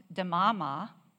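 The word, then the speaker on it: demama (דממה): Now, the actual Hebrew term here is this. It's pronounced demama.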